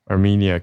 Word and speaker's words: Armenia: 好，Armenia、 Armenia 跟 A Azerbaijan 哦，好好异国的声音哦。